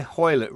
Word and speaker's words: Hoylett: Well, moving on, I've got written down here, Hoylett rhymes with toilet.